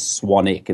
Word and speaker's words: Swanwick: Yeah, my last name is spelled Swanwick. Right. So it's Swanwick. But the correct pronunciation is Swanwick. It's the silent W. Just, you know, like Warwick. The name Warwick, it's Warwick.